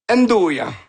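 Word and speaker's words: 'nduja: Le truc dans le milieu, ça, c'est un truc qui se prononce très mal, c'est le Ndjuna? – Ndoya. – Bon. – Ndouya, c'est pas loin.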